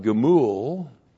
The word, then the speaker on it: Gemul: And then the noun gemul, looks like that. That's G, Shiva, M, long U, L, Gemul.